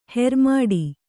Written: ಹೆರ್ಮಾಡಿ

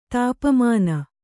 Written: ತಪಮಾನ್

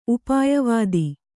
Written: ಉಪಾಯವಾದಿ

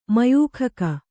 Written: ಮಯೂಖಕ

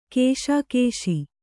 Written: ಕಸಕಸೆ

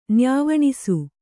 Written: ನ್ಯಾವಣಿಸು

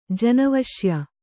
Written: ಜನವಶ್ಯ

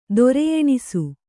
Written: ದೊರೆಯೆಣಿಸು

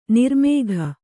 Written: ನಿರ್ಮೇಘ

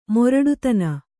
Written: ಮೊರಟುತನ

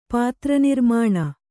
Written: ಪಾತ್ರನಿರ್ಮಾಣ

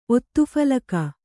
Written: ಒತ್ತುಫಲಕ

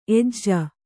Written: ಎಜ್ಜ

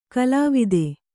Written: ಕುಲಾವಧಿ

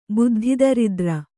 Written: ಬುದ್ಧಿದರಿದ್ರೆ

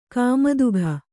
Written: ಕುಮುದಿಕೆ

